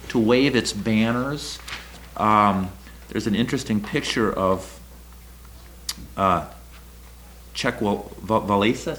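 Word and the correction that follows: Lech Wałęsa: Lech Valesa.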